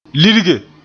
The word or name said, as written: lirge